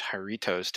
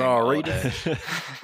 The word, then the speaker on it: Jarritos: And then we just added Jarritos to it. – Ooh, he said it right. – Yes. – Don't you mean Jarritos? – I've been practicing. Jarritos.